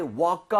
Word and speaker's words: woke: 그리고요, 주의하셔야 될 게 이 과거형 발음할 때요, walk 하지 마세요. – 어? – 그거는 걷다잖아요. – 아, walk. – W-A-L-K, right? Walk. So, I woke up 이렇게 하지 마시고요. 응. I woke up. 거의 5에 가깝게 발음하는 와, 이거 진짜 몰랐어요.